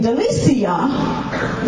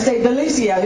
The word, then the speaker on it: Delicia: And the real reason I didn't need to give her a name, a nickname, there are two syllables in Duanice. In Delicia, there are three syllables. So when I would go on a rampage, I would get tongue-tied trying to say Delicia. It was just too much to say. So I started saying Lisi, because you can put emphasis on it when you're mad. You know, Lisi! Get in here, girl! I didn't have to do that with Duanice. I already had my two syllables.